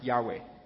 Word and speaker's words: Yahweh: So in the Old Testament, when you see the capital letters L-O-R-D in the original Hebrew, it was Y-H-W-H. And for us, it's been commonly accepted that it's pronounced Yahweh.